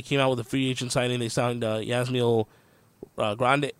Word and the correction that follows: Grandal: Yasmani Grandal.